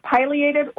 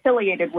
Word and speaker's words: pileated: And I was fascinated, you know, depending on what part of the country you're from, you can pronounce it pileated or pileated woodpecker.